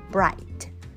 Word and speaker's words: bright: Bright, B-R-I-G-H-T, bright, G-H 不发音哦，所以 bright, 明亮的， red, 红色。